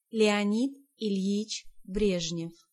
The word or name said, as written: Brezhnev